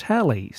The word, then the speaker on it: Halle: that's spelled H-A-L-L-E, and I have a friend whose dog's name is spelled that way, but it's pronounced Hallie, so take your pick.